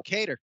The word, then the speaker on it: Kattar: Man, Kattar, I'm saying his name wrong, obviously. – No, Kattar.